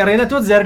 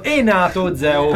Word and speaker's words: Renato Zero: Invece è Renato Zero, è diventato Enato Zeo.